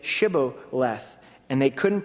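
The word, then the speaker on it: Shibboleth (שבולת): If he said, 'No,' then they would say to him, they say, 'Shibboleth,' and he would say, 'Sibboleth,' for he could not pronounce it right."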